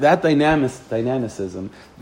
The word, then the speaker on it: dynamicism: So that dynamicism, I'm saying it incorrectly, but it's No? Dynamicism? I said it right? Okay, good. Luck. So that dynamicism that Chassidus embraces, the whole...